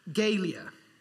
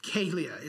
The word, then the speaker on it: galea: You know, the Roman helmet, the Galia, Galia, it's spelled G-A-L-E-A.